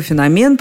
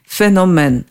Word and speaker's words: феномен: А вот из историко-этимологического словаря Черных я узнала, что феномен – произношение устаревшее, то есть так говорили раньше. Все дело в том, что в русский язык в XIX веке слово пришло из французского «феномен», а там ударение всегда на последнем слоге.